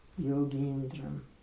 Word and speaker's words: Yogindram: Yogi Indram, Yogindram. Long E. That's why it has this horizontal line with the E. It's a long E.